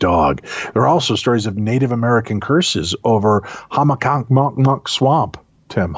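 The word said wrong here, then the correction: Hockomock: Hockamock Swamp. I don't know why my mouth refused to work with me there, but it's Hockamock Swamp.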